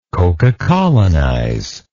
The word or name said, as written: cocacolonize